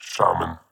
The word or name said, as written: Shaman